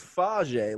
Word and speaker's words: Fage: Mark Fags. I don't know if I'm pronouncing the last name right. – I think it's Fage, like yogurt.